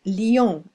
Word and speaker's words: Lyon: Hi, I am from Quality Time ESL, located in Grenoble in France, to the east of the city called Lyon, pronounced Lyon in French.